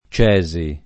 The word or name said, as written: Cesi